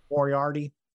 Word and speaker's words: Moriarty: First, Stokely Hathaway on the previous night's Rampage episode got Lee Moriarty to, is it Moriarty? I don't know how to say his last name. Moriarty. – Yeah. – I'm not good with names. I don't. I nailed it.